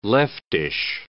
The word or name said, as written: leftish